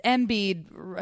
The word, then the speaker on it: Embiid: We don't even know what these kids, Parker, Wiggins, Embiid, I think that I pronounced his name right, yep.